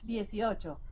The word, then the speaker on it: dieciocho: Is it dieciocho? Dieciocho? Hey Google, how do you say 18 in Spanish? – In Spanish, that's dieciocho.